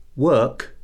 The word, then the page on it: work: verb